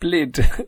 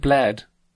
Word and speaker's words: Bled: I know it's pronounced Blid. – You know it's pronounced Blid. – A lot of people will pronounce it Bled, because it's spelled Bled.